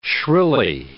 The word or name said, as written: shrilly